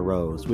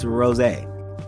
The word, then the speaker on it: Rosé: So the next person after Jennie, after Rosé, some people call it Rose, which is weird, T. They say Rose. And I say, some of my friends are like, oh, you mean Rose? No, it's Rosé. That's how you pronounce the name.